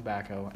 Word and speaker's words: Tabbaco: I told Brawley that it was Tabbaco. Tobacco. Not tobacco.